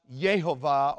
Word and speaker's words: Yehovah: And understand that if the Hebrew manuscript with the vowel pointing uh, is right, which we believe it is, it's not pronounced Yahweh. It's pronounced Yehovah or Yehovah.